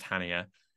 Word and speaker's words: Chania: It's spelled C-H-A-N-I-A, but I think it's pronounced Hania.